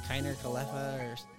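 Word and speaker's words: Kiner-Falefa: Yeah, and then they traded away their big, right, was it Kiner Khalefa? – Yeah.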